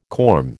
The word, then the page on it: corm: American English